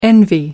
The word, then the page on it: envy: American English